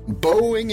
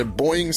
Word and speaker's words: Boeing: Boeing är det mycket riktigt, eller Boeing som man kan uttala om.